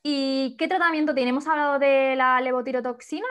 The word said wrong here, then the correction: Levotiroxina: ¿lo he dicho bien? – Levotiroxina. – Levotiroxina, casi.